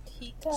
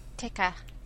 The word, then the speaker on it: Tikka: Tika? – Spell that for me. What? – T-I-K-K-A. – Tika. – Tika.